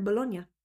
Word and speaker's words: Bologna: So I've always said Bologna, but I realize now it sounds kind of funny if you haven't heard it before. So that is how you say the word Bologna.